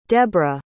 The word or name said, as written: debrah